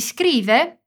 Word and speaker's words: scrive: Aqui você precisa se concentrar na pronúncia de legge, que tem dois Gs, e na pronúncia do S em scrive, que não tem uma vogal antes. Por isso não dizemos nem escreve, nem escreve.